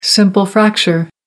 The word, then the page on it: simple fracture: American English